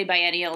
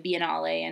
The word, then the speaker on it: biennale: We say biennial instead of biennale and triennial instead of triennale.